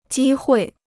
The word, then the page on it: 机会: jī huì